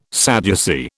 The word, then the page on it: Sadducee: British English